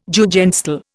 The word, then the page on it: Jugendstil: Indian